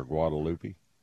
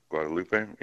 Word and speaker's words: Guadalupe: Guadalupe, okay. Mm-hmm. Or Guadalupe. – Guadalupe, yeah. So I'm pronounce it like, several ways, yeah.